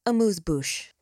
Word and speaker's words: amuse-bouche: This week, we're bringing you the first two episodes of our six-part investigative series as a little, how do you say, amuse-bouche. – That's how you say it.